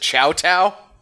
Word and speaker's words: Chateau: There is a major street in St. Louis that's spelled like Chateau, but everybody here pronounces it Chow-Tow.